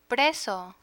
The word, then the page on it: preso: Spanish